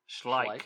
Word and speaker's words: Schleich: So during all that, so back in 65 when Slyke, whatever you called him, sorry, I butchered that name. How do you say it again? – Schleich. – Kellick? Schleich? Schleich.